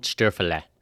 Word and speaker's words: Stöferle: And please welcome to the show, Ronald Stöferle.